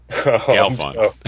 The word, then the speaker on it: Galfond: Galfond. The stress is on the gal. I think it's Galfond.